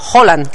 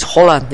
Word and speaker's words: Haaland: que él quiere que le llamemos Haaland, que esa es otra. – Haaland. – Sí, sí, lo dijo en la pronunciación de la FIFA. Dijo, my name is Haaland.